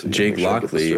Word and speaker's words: Jake Lockley: I think that's for sure happening next week that they're going to do that Jake Lockie. Or Lockley. How do I say it again? – Jake Lockley. Explain that.